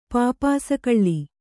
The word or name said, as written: ಪಾಪಾಸುಕಳ್ಳಿ